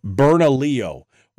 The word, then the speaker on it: Bernalillo: And when the sheriffs uh, of the different areas, including the shen- the sheriff of Burnt oh, oh, I'm sorry. Somebody corrected my pronunciation because I kept murdering it, and I said I murdered it, and then um, uh, Jarrett was kind enough. Bernalillo, when the sheriff of Bernalillo County came in and said, uh, "No, uh, sorry, unconstitutional.